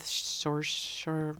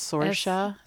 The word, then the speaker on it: Saoirse: Did you see Lady Bird, the movie that she made with Saoirse Ronan? – Yeah, how do you pronounce that? – I think it's Saoirse.